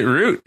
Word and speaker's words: route: because you can pronounce it root if you want. – Okay.